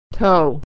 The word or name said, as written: toe